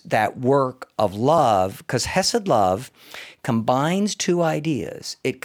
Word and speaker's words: chesed: The word is pronounced chesed, but you kind of spit when you say chesed yeah.